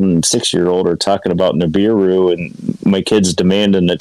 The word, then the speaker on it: Nibiru: I'm not saying it right, am I, Mike? – Nibiru. – New beer. Yeah, beer, right. Ash taught me.